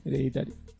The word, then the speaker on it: Hereditary: Vos dijiste herencia o algo así, no es Hereditary. No. – ¿Hereditary? – Sí, Hereditary. – Sí, bueno, yo dos de las tres veces lo dije mal.